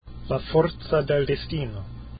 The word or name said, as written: forza